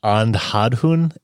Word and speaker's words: Andhadhun: So the movie I'm recommending is called Andhadhun, and it's spelled A-N-D-H-A-D-H-U-N.